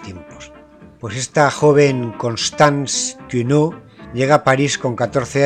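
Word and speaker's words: Quéniaux: se escribe Kenny Aux y se pronunciará Cuneau.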